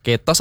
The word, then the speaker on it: ketos: Bacanya betul ketos gak sih? – Iya. – Ketos atau ketos? – Fakta. Ketos. – Ketos. Ketos gitu ya? – Iya.